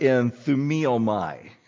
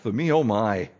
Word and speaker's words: enthumiomai: I wish we could all learn the Greek and read this in the original language, because the word for thought is the Greek word enthumiomai. And then I read that and I think, oh, thumiomai. Oh, my. The Greek word thumos is where I got to see it to say it right.